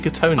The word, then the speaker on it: Katona: You mean Kerry Katonia? – What did I call her? – Kerry Katona.